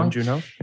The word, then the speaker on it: Bong Joon-ho: Bong Joon-ho, yeah.